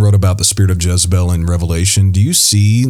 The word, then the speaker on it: Jezebel: Jezebel, yes.